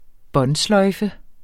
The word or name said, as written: båndsløjfe